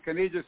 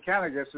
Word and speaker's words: Kanegis: I can accept that lots of pronunciations. Kanegis is okay, but actually Kanegis is how I pronounce it.